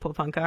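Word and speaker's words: Porepunkah: Well, congratulations for pronouncing it correctly. – Oh, well, I'm a Victorian. I've been past Pōpunka, I know. – Oh, well, of course. Yeah, that's right. You are, aren't you? but no, people mispronounce it all the time.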